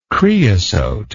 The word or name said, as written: creosotic